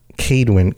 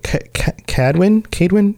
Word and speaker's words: Kaedwen: But I hope you guys enjoyed this episode about Kaedwen. Kaedwen? You say it differently. – Kaedwen. – You say Kaedwen. Did you know Kaedwen. That the A-E sound in Old English is pronounced ah?